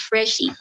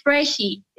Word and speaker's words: Phreshy: My name is Phreshy with a P. Just like how you pronounce phone Faro. It's Phreshy.